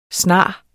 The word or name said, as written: snarest